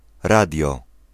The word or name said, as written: radio